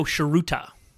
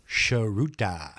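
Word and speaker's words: Tsuruta: Jumbo Sheruta. – Sharuta. – Yeah. – My Sharuta. – You don't spell it the way it sounds. It's definitely, that's like a TS something.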